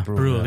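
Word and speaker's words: Brühl: Yeah, Bruhl, yeah.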